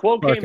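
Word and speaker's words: Farke: But what's the what is fodder, the quotes from, is it Daniel, is it Fark or Farky? I never know how to say his name. – The quote Farka.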